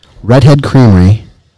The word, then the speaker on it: Redhead Creamery: And today we are at Redhead Creamery.